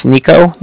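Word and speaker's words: Niko: It's Nico. That's N-I-K-O. – Okay. – And then the Bank of Cardiff is spelled C-A-R-D-I-F-F, as in Frank, so it's a car and the different.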